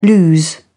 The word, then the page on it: lose: British English